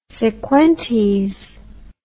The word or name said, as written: sequentes